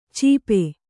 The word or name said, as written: ಚೀಪೆ